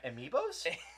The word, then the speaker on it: Amiibo: He has to buy more Ambios. – Yeah. – Amiibos? – Amiibos. That's what he meant to say.